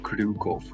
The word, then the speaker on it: Kryukov: It's pronounced Nikita Kryukov.